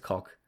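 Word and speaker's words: Koch: It could be a joke that I've not read into it properly, but like, yeah, I've heard a few people talk it, like, pronounce it as cock now. – I've called him kosh for so long.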